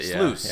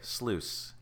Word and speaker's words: Sluice: I think that's how they pronounced it. – Sluice. – Yeah. – Okay. Sluice.